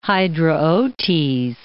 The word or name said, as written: Hydraotes